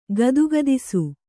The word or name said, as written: ಗದಗದಿಸು